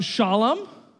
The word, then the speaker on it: Salem: And in the English Bible, it says Salem. Um, it, would be kind of more closely pronounced uh, Shalom, right?